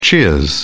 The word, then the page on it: cheers: British English